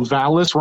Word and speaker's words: Valis: It has harmony with Dallas, D-A-L-I-S, and M-A-L-I-S. And Dallas has a dark history of malice. I just wanted to point out how Valis rhymes with these two very significant other words. We're taking liberties on the spelling, but the sound is what's important here.